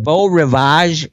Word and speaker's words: Beau Rivage: He's got a great event, the Beau Rivage, is that correct, in Biloxi? Did I pronounce it correctly? That's right.